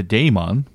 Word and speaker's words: daemon: No, the daemon, which actually is the proper way to say it, supposedly. Um, even spell it like D A E M O E N, something like that. Mm-hmm. It's like yeah. Daemon. Yeah. – Or pronounced demon, but it's spelled daemon.